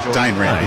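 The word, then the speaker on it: Dane Rampe: Was that Dan? – Dane Rampy. – That's the one. – Little silent E there at one stage.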